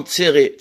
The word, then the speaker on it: tséré: Ne dis pas, Altikre Betzara, ne dis pas Betzara dans le malheur, et la Betzere, dis avec lui en Tzere. Tzere, c'est une voyelle qui s'écrit, qui se prononce « et » avec deux points qui sont sur la même ligne.